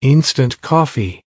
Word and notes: instant coffee: American English

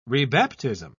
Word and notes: rebaptism: British English

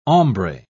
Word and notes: hombre: British English

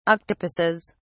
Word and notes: octopuses: British English

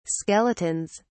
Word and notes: skeletons: British English

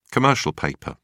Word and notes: commercial paper: British English

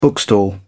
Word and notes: bookstall: British English

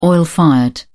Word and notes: oil-fired: British English

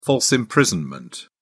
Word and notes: false imprisonment: British English

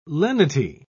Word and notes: lenity: British English

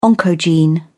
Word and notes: oncogene: British English

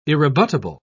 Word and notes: irrebuttable: British English

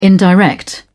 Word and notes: indirect: British English

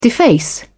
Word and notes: deface: British English

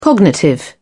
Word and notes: cognitive: British English

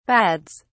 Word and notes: bads: British English

